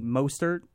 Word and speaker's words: Mostert: You know, they have a great running game now with, I think, Mostert.